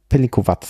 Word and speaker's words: Pelinkovac: Und der wird, also jedenfalls hier auf der Flasche, ohne, ja, ich glaube, das ist richtig, dass da kein Akzent, also Pelinkovac. Also das ist jetzt ein Beispiel dafür, dass das C hinten wie ein Z ausgesprochen wird.